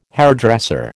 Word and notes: hairdresser: American English

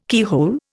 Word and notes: keyhole: Indian

